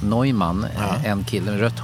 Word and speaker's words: Neumann: Och det finns mycket judisk humor, men när man uttalar det på engelska så blir det ju Alfred E. Neumann, alltså inhuman.